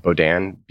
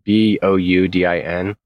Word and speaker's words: Boudin: I forget how you pronounce it. It's like Bodin. Boudin.